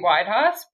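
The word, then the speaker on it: Weidhaas: So that's Sophie Weidhaus, but I'll spell it SophieWeidhaas. That's two A's, one S.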